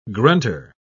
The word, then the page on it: grunter: British English